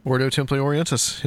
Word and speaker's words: Ordo Templi Orientis: Got Ren Collier, guys, and we're talking about Ordo Templi Orientis. I think I said that right. – That's right. – Ordo Templi Orientis, yeah, the OTO, and that's what will be the focus of this show.